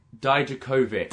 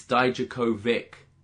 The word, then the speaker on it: Dijakovic: Dominic Dijakovic. – Dijakovic. – As Mauro said, guys, we got to scratch the itch. It's Dijakovic. – Wow. – Not Dijakovic.